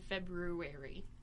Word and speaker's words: February: and then he went to Memphis in February. – February? – No one pronounces the R, and I don't know why.